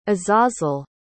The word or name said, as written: azazel